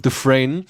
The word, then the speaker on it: Dufresne: der den Andy Dufresne, wie wird das ausgesprochen? – Dufresne.